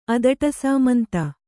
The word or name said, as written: ಅದಟಸಾಮಂತ